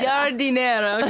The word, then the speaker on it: Giardiniera: Um, and also, um, MSU performed one of Mozart's first operas this year, um, La, La, Finta um, Giardiniera. – Giardiniera.